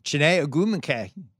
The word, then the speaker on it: Chiney Ogwumike: All right, also in Los Angeles, where I am, Chiney Ogwumike. Did I say it correctly? – You nailed it.